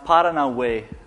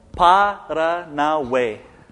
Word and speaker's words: Paranauê: The first one, which is now over on the left side, is pronounced Paranauê. So we'll break it down into syllables. Paranauê.